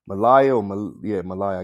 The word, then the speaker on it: Malia: Says Michelle and Barack Obama's daughter, Malia, I guess that's how you say it. – Malia. – Obama. – Malia? – Okay. Yeah.